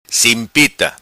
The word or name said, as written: Simpita